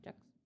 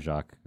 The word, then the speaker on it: Jacques: Jacques. – I think I Brian Jacques, or I don't know. He's got an S in the end, but yeah. The French like silent letters, so yeah. Who knows.